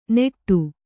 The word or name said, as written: ನೆಟ್ಟು